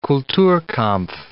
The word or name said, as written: Kulturkampf